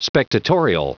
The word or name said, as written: spectatorial